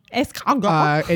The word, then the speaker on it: escargot: Escargot.